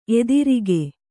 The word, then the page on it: ಎದುರಕ್ಕಿ: edurakki